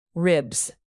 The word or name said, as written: ribs